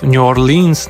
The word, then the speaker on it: New Orleans: Perfekcyjne pronunciation tych wszystkich nazw. New Orleans na przykład. Bardzo dobrze, bardzo dobrze.